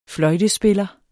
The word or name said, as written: fløjtespiller